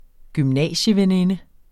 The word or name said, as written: gymnasieveninde